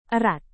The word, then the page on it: erat: indonesian